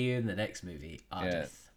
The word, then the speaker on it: Ardeth: Adrif, big Ardeth.